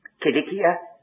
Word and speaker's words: Cilicia: Well, then again, you do know that Cilicia is spelt with a K. And if you don't know it, well, there it is. There's no soft C.